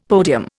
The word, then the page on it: podium: Indian